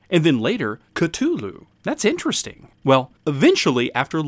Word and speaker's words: Cthulhu: Not to mention the beginnings, the outline of what would become The Call of Kalalu. – Wait, is that how it's actually pronounced? – According to Lovecraft himself, it is, dear boy. It is meant to be a guttural sounding name.